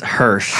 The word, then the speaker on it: Hirsch: Ty, what is your last name and how do you say it right? – It's Hirsch. – Hirsch. – Like Hershey's, like the candy.